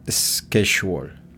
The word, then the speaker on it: schedule: Y schedule es S-K-E-C-H-U-O-L. – On schedule.